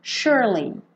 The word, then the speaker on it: surely: Então, esse S vai ter som de SH, sh. E como o E do meio não é pronunciado, você enrola a língua no R, sure, e já vem com o li, surely. tá?